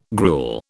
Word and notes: gruel: British English

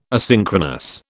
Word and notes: asynchronous: British English

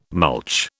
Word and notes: mulch: British English

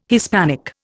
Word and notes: Hispanic: Indian

